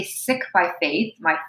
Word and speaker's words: Sikh: It is spelled S I K H. Many people pronounce it, including my own people, pronounce it as Sikh. That is not the correct pronunciation. That is the colonized pronunciation.